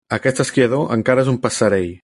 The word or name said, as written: passerell